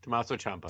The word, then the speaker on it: Tommaso Ciampa: Is it Chompy? Is that pronounced correctly? – Chompy's that big black thing from the Mario game. – No, that's Moose. Oh, I like Chompy better. – Champa, Tommaso Ciampa. – Tommaso Ciampa. Thank you.